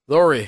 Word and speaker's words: Lori: Jordan and Laura Harvey break up. – Lori Harvey. Lori. And why did I say it wrong again? I was trying that time too. Lori? – Lori, yeah. Lori. L-O-R-I.